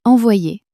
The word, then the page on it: envoyer: French